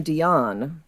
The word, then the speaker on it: Dion: By the way, I would pronounce his last name Dion. – Okay. Like Celine? – Yeah.